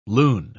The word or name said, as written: lune